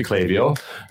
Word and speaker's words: Klaviyo: I learned how to say it, pronounce it right, because I just ran into them. So it's actually Klaviyo, and they told me that.